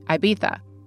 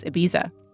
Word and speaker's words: Ibiza: Just a note here on pronunciation, my British friends would say Ibiza, but I'm going to go with the U.S. pronunciation here, which is Ibiza.